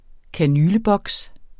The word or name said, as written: kanyleboks